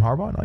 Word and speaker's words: Harbaugh: Jim Harbaugh? No, you're saying it right.